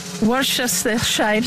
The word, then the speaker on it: Worcestershire: De Worcestershire sauce.